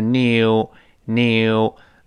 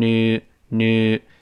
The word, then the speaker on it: new: 这个 new 我们说美式发音，英式发音有个强烈的耶在里面作怪，都是 new new。美音呢，这个耶就几乎听不清了，变成 new new。